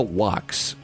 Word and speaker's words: Wach: Don't forget, Manny the Movie Guy will join us, and we're going to talk with Dr. Dahlia Wax. How do you think she says it? Any idea, Brooke, how she says it? – I think it's Wax. – It's Wax, like W-A-X, but it's spelled W-A-C-H.